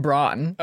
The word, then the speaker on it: Brann: And it's spelled B-R-A-N-N, right? – Absolutely. – But you don't say it like that. Now, here's where you're going to get a kick out of it. It's pronounced Braun.